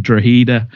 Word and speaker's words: Drogheda: Sean Rowan and Sam Long both joined Drogheda on loan, which it's funny we've both pronounced that right. – Yes, because we had to Google it before we started. – Well, I didn't want to say Drogheda if it was Drogheda.